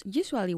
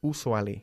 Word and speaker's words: usually: Muchísimos españoles tienden a decir usually. – Mm-hmm. – Y no es así. Es you, como you, tú. Usually.